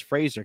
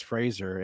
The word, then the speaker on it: Fraser: I learned that it's Fraser because he's corrected Adam Sandler. He said it's Fraser. It rhymes with razor.